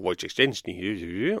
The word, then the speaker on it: Wojciech Szczęsny: Så der står Wojciech Szczęsny og sier til polsk fjernsyn Wojciech Szczęsny og så ser han I kamera til Arsenal TV og sier Wojciech Szczęsny.